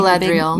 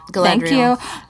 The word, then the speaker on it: Galadriel: Is fighting Galadriel. – Big, me. – Galadriel.